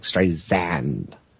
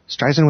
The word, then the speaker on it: Streisand: Siri pronounces Streisand with a hard S, like Streisand. This is completely wrong. So Streisand with a soft S, like sand on the beach.